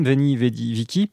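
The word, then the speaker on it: Veni Vidi Vici: Et puis bah, vous avez eu le droit en outro de l'interview à un extrait du dernier album Veni Vedi Vici. J'insiste sur la prononciation du que, hein, qui et pas de chi, puisque c'est du latin et pas de l'italien.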